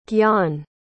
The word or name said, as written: Gyan